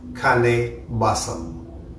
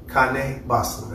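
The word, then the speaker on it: Kaneh Bosm: Anciently known as Kane Basam. Kane Basam. That's K-A-N-E-H B-O-S-M. That is the ancient Hebrew or ancient Aramaic pronunciation of the word phrase that they use to describe the plant that today we call marijuana or cannabis.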